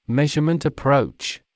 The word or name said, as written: measurement approach